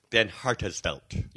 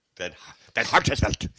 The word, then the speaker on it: Van Hartsveld: So it's all strong vowels. Van Hartsveld. Van Hartsveld. – Van Hartsveld. – Yep. – Van Hartsveld.